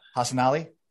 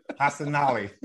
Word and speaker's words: Hassanali: Hassanali? Yeah. There we go. Cool. – It's not that hard. It's not that hard. Hassanali. It's just like it's spelled. – Yeah. – Hassanali.